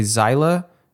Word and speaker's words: Xyla: By the way, for those of you who do want to Google this, a Zeiler 21 chart is spelled XYLA.